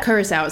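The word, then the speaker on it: Curaçao: And so now I'm like Curacao. – Yeah, yeah. – Is that right? – So... – I hope that's right. – Curacao is correct.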